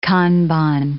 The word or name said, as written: kanban